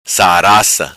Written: Saraca

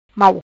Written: Mau

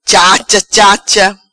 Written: Chacha Chacha

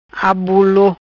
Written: Abulo

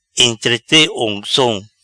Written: Entrete oncom

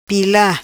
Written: Pila

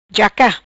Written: Jaca

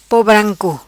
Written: Po branco

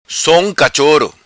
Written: Som Cachoro

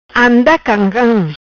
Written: Camcam Anda

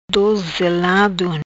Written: Dozelado

